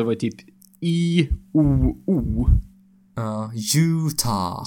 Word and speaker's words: Utah: Ja, det är Utah.